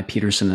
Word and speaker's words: Pedersen: Now, my Pedersen is the Danish one, so it's spelled P-E-D as in Delta, E-R-S-E-N. Most people get that wrong. Most people use the Swedish one, which is all O's and T's.